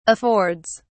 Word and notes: affords: British English

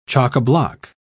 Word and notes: chockablock: British English

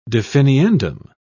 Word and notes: definiendum: British English

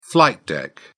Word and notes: flight deck: British English

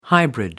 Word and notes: hybrid: American English

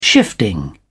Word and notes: shifting: British English

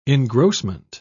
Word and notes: engrossment: British English

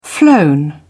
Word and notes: flown: British English

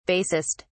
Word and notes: basest: British English

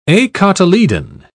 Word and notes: acotyledon: British English

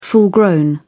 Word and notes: full-grown: British English

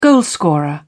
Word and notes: goalscorer: British English

